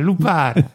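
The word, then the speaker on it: lupara: How do you say it, Sicilian? – Gotara. – No, that was what Karen Allen said in Nepal in Raiders of the Lost Ark. – Bastare. – Bastare. Bastare. Lupare. Lupare. Indeed, the police this is fascinating.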